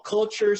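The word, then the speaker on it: Quture: Her company's called Culture, spelled with the Q, Q-U-T-U-R-E.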